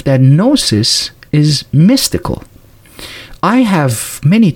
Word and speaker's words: Gnosis: For the Gnostics, when someone tried to gain salvation, which they called Gnosis, by the way, this Gnosis, Gnostic with the silent G in the beginning, this is the same word that we have the word knowledge today with K-N-O-W, and this is G-N-O.